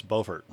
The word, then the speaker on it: Beaufort: Isn't that one Beaufort? – Yes, Beaufort.